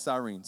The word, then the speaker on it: Cyrene: There's many, many pronunciations for that last name, and I'm going with Cyrene, so judge me if you want.